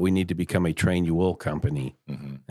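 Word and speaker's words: Trainual: train-you-will, the way it's spelled, train, U-A-L, is a platform we have found.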